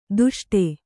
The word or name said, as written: ದುಷ್ಟೆ